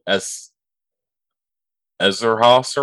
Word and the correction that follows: Eszterhas: or whatever. Oh, right. Eszterhas. Eszterhas, yeah.